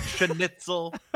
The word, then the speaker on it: schnitzel: Why is he adding a syllable? Schnitzel. – He's adding like three syllables.